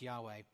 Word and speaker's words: Yahweh: That's how he thinks it's pronounced, Yahweh.